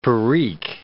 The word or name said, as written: perique